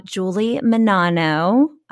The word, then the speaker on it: Menanno: You guys can't see her, but Julie Menanno. I said that right? – You did.